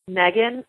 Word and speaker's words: Megan: And so if you visit my website, the website is Megan.